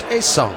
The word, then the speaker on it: Aesung: His translation of his first name in Korean is Comet. Pronounced Aesung.